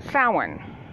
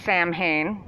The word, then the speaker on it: Samhain: called Samhain. I've also heard it pronounced Samhain, whichever you're more comfortable with.